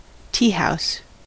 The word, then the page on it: teahouse: American English